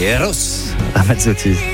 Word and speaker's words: Eros Ramazzotti: Eros Ramazzotti. On est obligé de prononcer les R un peu différemment quand on dit Eros Ramazzotti.